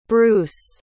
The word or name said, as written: breuse